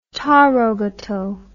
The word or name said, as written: tarogato